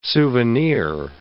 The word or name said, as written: souvenir